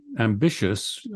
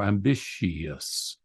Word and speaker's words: ambitious: Both the pronunciation of the day, the OP, the original pronunciation, plus the dictates of scansion require that ambitious, a three-syllable word for us, is sometimes three, but sometimes four, ambitious.